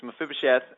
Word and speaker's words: Mephibosheth: And you talk about Mephibosheth. I think I'm saying his name right. Talk about that one. – Well, yeah, this is the fun chapter where I always love doing these interviews, because it's Mephibosheth.